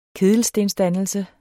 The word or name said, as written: kedelstensdannelse